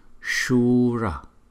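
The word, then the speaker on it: śūra: Shura, chữ S có dấu sắc, và chúng ta sẽ đọc là Sha. Và chữ U có dấu gạch ngang thì chúng ta đọc hơi kéo dài hơn một chút. So với chữ U không có dấu gạch ngang thì chúng ta đọc là Shura, Shura.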